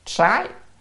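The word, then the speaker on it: sái (灑): Thủy, chữ này là đọc là sái,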